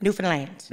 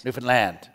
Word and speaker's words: Newfoundland: Newfoundland. – Newfoundland.